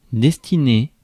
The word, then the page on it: destiner: France; [dɛs.ti.ne]